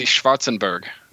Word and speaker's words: Schwarzenberg: That is the Schwarzenberg coat of arms. – Schwarzenberg. – It's the Czech and German, one of the most prominent European noble houses. Princes of the Holy Roman Empire. – Schwarzenbergen, yep.